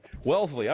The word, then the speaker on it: Wellesley: Is that how you pronounce that? Wellesley? I got to figure that one. Wellesley. Okay, there we go.